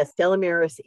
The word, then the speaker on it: Stella Maris: Stella Maris, am I saying that right? – Yeah, Stella Maris is the Blessed Mother Mary of the Sea.